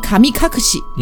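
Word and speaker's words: 神隠し: 嗯，那我们来说日本的这个神隐啊，日本的这个神隐呢，它写作中文是神隐这两个字，然后加一个那个片假名西，所以它读作卡米卡克西。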